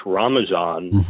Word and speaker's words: Ramazan: And while it's called Ramadan in much of the world, that's an Arabic origin, it's pronounced Ramazan in Afghanistan and also Iran and other areas.